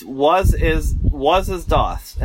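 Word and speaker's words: Was ist das: It's Was Is Dost, and it's spelled W-A-S space I-S-T space D-A-S.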